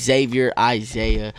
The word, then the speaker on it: Xavier Isaiah: It's Xavier Isaiah. I got all my music. I got like 50-something tracks. XavierIsaiah. Simple as fuck. Sounds how it's spelled.